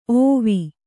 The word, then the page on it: ಓವಿ: ōvi